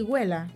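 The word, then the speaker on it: Guéla: Se dice así, Güela.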